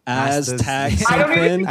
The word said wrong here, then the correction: astaxanthin: I almost said Xanax. – Astaxanthin. – Yeah. – Okay. Astaxanthin.